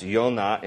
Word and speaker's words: Yonah: His name, Jonah, there's no J in the Hebrew alphabet. It's just a Y, so his name is pronounced Yonah in Hebrew, which translates dove.